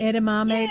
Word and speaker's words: edamame: Is that how you pronounce it? The little edamame. – Edamame, yeah, beans.